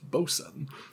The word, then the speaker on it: boatswain: Technically, that word is pronounced bosun. – Whoops. Well. – Although that might be a British pronunciation.